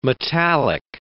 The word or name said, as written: metallic